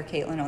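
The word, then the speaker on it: Katelind: My parents got a little creative with the spelling of my name, even though it is pronounced like every other Caitlin on the planet. Mm-hmm. I'm probably the only Caitlin with a D on the end.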